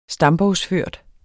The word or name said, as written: stambogsført